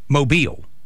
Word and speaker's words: Mobile: Second syllable. So, Mobile. Mobile. – Oh, Mobile. – Emphasis on the second. So, Mobile. And you do that, Mobile.